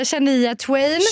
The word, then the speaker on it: Shania Twain: Shania Twain. Uttalar man henne så? Nej, det gör man inte. Jo, det var bra. Nej, det tror jag inte var bra. Oh, ja. Shania, Shania Twain.